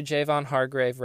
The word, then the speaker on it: Javon Hargrave: Now, I butchered his name so bad. And I realized it, too, as I was saying it. I was like, ooh. So let's just do Javon Hargrave right here.